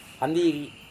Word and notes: hambiri: Serewen

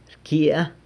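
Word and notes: rcyia: San Lucas Quiaviní Zapotec

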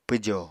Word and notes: pwjo: Apatani

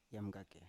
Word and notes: yamgake: Biak

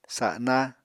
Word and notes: sana: apatani

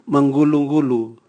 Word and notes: mangulungulu: Raga

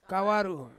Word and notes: kawaru: Ansus

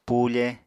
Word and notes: pufe: apatani

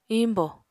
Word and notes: iñbò: apatani

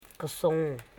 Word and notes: kosong: ko.song

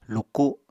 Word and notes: luku: Apatani

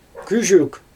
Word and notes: küzhük: Xyzyl

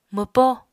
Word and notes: mwpó: Apatani

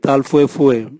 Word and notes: tal vue vue: Raga

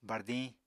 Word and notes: bardiñ: Apatani

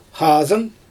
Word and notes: xaazɯn: Xyzyl

